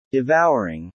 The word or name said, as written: devouring